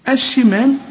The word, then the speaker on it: الشمال: طبعا ارجع للشريط واعرف حكم هذه الكلمة ونطقها ازاي علشان ما تقولش وأصحاب، قل لي وأصحاب الشمال. الشين عليها الشدة والحرف المشدد بحققه لكن ما قلنوش.